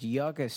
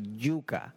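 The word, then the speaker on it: yuca: And I remember the street I was staying in was Yucca Street. – Right. – But in Puerto Rico, in Spanish, we pronounce it Yucca.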